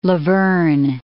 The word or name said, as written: La Verne